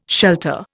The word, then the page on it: Shelta: Indian